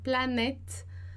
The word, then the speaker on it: planète: Então, colocar do ladinho do complètement, planète, então aquele acento ali, a gente vai pronunciar E, planète, e o segundo E sem o som, né, planète, e découverte, depois com som de E, né, então, só vou subir aqui, vou colocar o rêve do lado do planète, porque rêve, né, vai acabar que tem som de E também.